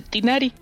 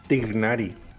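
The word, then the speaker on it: Tighnari: Eh, mira, no quiero aquí que me, que me quemen porque no pronuncie bien el nombre del personaje, pero para mí se dice Tinari. – Tinari. Tignari.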